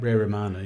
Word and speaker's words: Romano: Ray Romano, yeah.